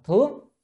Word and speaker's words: thướng: còn chữ chữ này không phải đọc là thượng mà đọc là thướng,